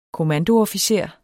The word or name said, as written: kommandoofficer